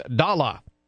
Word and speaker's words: Dolla: His name is Dolla. I'm not using an East Coast accent. It is spelled D-O-L-L-A.